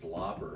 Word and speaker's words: Lauper: They're being very sarcastic and they're trying to say, yeah, and I'm Cindy Lauper, and they say the name wrong. Lauper, because it's Lauper, right? – Yes.